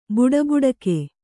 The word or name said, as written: ಬಡಬಡಿಕೆ